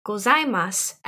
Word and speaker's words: gozaimasu: Now, if you want to learn how to say it the more formal way, then you can add gozaimasu at the end. Gozaimasu. Gozaimasu. Ohayo gozaimasu. Ohayo gozaimasu. You want to try that one, Shannon? – I guess I should. All right. Ohayou gozaimasu.